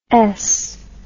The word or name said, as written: esce